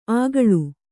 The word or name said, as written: ಅಗಳು